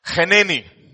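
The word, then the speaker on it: Heneni (הנני): Heneni. Actually, Heneni. For those of you who like to pronounce the het. Heneni.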